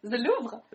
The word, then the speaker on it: Louvre: Is that pronounced correct? – The Louvre.